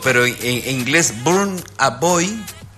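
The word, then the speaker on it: Burna Boy: Y justo escogieron un cantante que se pronuncia Burn a Boy.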